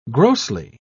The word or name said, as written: grossly